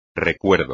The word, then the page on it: recuerdo: espagnol